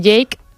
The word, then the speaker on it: JVKE: Ahora pasamos con Jake, que sí, lo que pasa es que se escribe J-V-K-E. – Pero bueno. – Utiliza la V como una A,